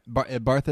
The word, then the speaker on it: Bartha: Bartha is how you say the last name, yeah.